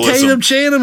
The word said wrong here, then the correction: Channing Tatum: his bitch. – Channing Tatum. Tatum Chanum is not a guy. – You couldn't say it right either. – Channing Tatum.